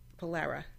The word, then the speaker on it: Polara: I would like to talk, before we go any further, about Polaris. – Polara. – Polara? – Yes. – That's the way I pronounce it? – Yes, Polara? – There is not an S on the end.